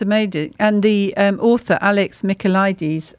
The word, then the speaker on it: Michaelides: I grew up in Greece. So for me, it was like, I know how to pronounce this name. I felt quite smug about that. – I'm so glad that you're here on the show. – Yes. – I shall defer to you in all Greek pronunciation. – Yes. Alex Michaelides. – Oh, there we go. Michaelides. All right. Yeah, it's good to have the right pronunciation.